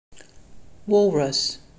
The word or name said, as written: Walrus